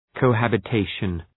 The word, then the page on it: cohabitation: kəʋ,hæbə’teıʃən